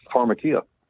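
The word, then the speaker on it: pharmakeia: That's right, because yeah, most people don't know how to pronounce that in its original, you know, Hebrew, Greek, Aramaic, but it's pronounced pharmakia, and it means the use or administration of drugs in association with witchcraft.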